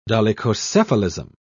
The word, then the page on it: dolichocephalism: British English